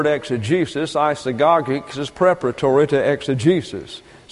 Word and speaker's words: exegesis: Exegesis is the way that is pronounced. Exegesis. And when you bring the E's over into the English, it sounds like a short E in our pronunciation. But an eta is long in the Greek and receives a pronunciation similar to the long A in English. So it's exegesis, and it means the interpreter.